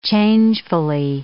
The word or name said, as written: changefully